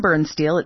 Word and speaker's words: Birnstihl: That is Jason Bernsteel. It's spelled B-I-R-N-S-T-I-H-L.